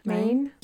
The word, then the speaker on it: Maen: But anyway, there is a park ranger or tour guide named, and I don't know how to say it, M-A-E-N, Maine. – Maine.